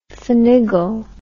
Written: fanigle

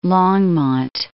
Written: Longmont

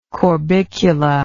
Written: corbicula

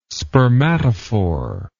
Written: spermatophore

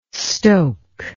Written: stoke

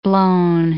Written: blown

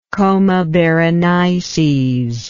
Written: coma berenices

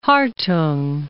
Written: Hartung

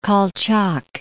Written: Kolchak

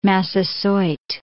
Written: Massasoit

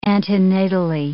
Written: antenatally

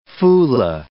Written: Fula

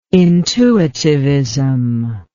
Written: intuitivism

